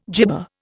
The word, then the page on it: jibba: Indian